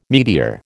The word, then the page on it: meteor: American English